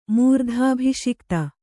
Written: ಮೂರ್ಧಾಭಿಷಿಕ್ತ

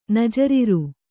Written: ನಜರಿರು

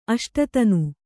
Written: ಅಷ್ಟತನು

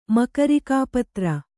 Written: ಮಕರಿಕಾಪತ್ರ

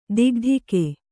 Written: ಥಕಥಾಕು

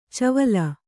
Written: ಚವುಲಿ

